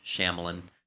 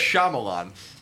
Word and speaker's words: Shyamalan: By the way, I say it is the Indian way, not the English Shyamalan. – How do you say it? – Shyamalan. Oh yeah. So. I know. I'll never. – It's Shyamalan.